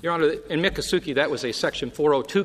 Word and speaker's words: Miccosukee: Yes, Miccosukee.